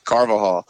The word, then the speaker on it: Carvajal: however the hell you say your last name. – Carvajal. – That's not too hard.